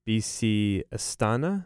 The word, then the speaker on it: Astana: I think it's BC Astana.